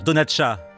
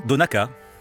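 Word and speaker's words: Donnchadh: Un Français aurait envie de dire Donatcha, mais on dit bien Donaka. – Yes, Donaka is what is how you would pronounce it. – Oui, c'est bien Donaka dans la langue irlandaise. – So the CH is a K. – Le CH se prononce K.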